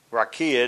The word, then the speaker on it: raqiya: Now, the firmament is translated from the Hebrew word rakia. Rakia, it's spelled R-A-Q-I-Y-A. Rakia, which means expanse.